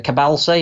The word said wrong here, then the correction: Kabasele: is it at Watford? – Uh, Kabasele, I believe. – Kabasele.